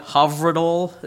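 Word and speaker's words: Hyfrydol: This one in Southern would be Heifridol, but it's probably pronounced more like Havridol. It's Welsh.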